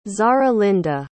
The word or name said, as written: zarahlinda